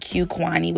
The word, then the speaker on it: Qquani: All you have to do is type up Grace and Q Quani, which is my name. It's spelled Q-Q-U-A-N-I.